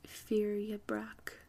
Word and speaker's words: fyrgebraec: Another one, it's spelled F-Y-R-G-E-B-R-A-E-C. So this is in Old English, which I don't speak, but luckily I think it's pronounced Fyrjabrak. Only from what I know about the A-E symbol.